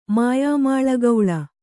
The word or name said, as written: ಮಾಯಾಮಾಳಗೌಳ